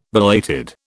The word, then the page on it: belated: British English